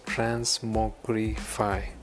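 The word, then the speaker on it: TransmogrifAI: This is pronounced as Transmogrify.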